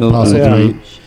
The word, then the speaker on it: Postlethwaite: Lucas Haas, Michael Caine, and Pete Postlethwaite, the great late Pete Postlethwaite, that is pronounced Postlethwaite. On the show one time, I said Postlethwaite, and everybody made fun of me saying it was Postlethwaite, and I couldn't prove it on the spot because...